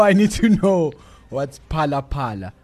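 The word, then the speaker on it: Phala Phala: And so it's Palapala. – Yes. – Not Falafala. P-H-A-L-A. – Yes. – Okay. – The H is silent.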